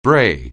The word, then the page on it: bray: anglais